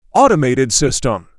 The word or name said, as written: automated system